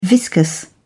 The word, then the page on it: viscous: British English